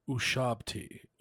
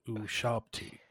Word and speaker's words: ushabti: It's an Ushabti. Ushabti. Ushabti. U-S-H-A-B-T-I-E. – Oh. – So I've been saying that wrong. Close, but wrong.